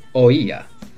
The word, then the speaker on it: oía: Otra palabra muy interesante es la palabra oía. Son tres letras y a la vez tres sílabas. O, I, There are three letters in this word, and each of them is a syllable.